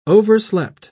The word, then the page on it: overslept: British English